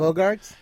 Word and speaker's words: Boggart: Yeah, the Boggart.